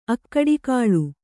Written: ಅಗಡುಗೊಳ್

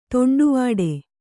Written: ತೊಂಡುವಾಡೆ

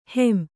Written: ಹೊಂ

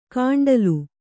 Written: ಕಾಂಡಲು

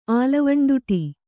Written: ಆಲವಣ್ಣದುಟಿ

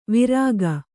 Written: ವಾರಕ

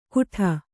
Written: ಘೂಟ

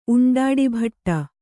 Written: ಉಂಡಾಡಿಭಟ್ಟ

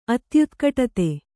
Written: ಅತ್ಯುತ್ಕಟತೆ

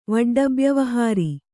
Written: ವಡ್ಡಬ್ಯವಹಾರಿ

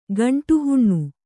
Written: ಗಂಟುಹುಣ್ಣು

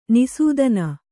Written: ನಿಸೂದನ

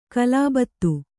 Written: ಖಲಬತ್ತು

